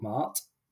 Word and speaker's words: Maat: But what this feather actually represents is an Egyptian goddess called Maat, which is spelled M-A and then a little stop and then A-T.